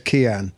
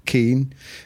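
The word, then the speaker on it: Cian: Well, he actually says Kian. – Yes. – The Irish pronunciation is Keen, but he says Kian.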